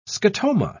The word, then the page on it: scotoma: British English